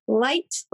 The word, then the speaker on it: Leicht: And my last name is spelled L-E-I-C-H-T. It's pronounced light like the beer.